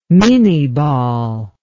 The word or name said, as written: minie ball